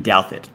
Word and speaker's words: Douthit: It's Pete Douthit. There's a T Douthit in there, but yeah.